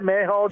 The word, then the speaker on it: Mayhaw: Mayhaw. Is that how you say it? Mayhaw jelly? – Yeah, that's right. That's right.